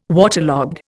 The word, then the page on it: waterlogged: Indian